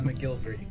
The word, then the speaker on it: McGilvery: How, do, how are we saying that, by the way? – Uh, McGilvery. – McGilvery. Okay, McGilvery. all right. I got got it right yeah. the first time.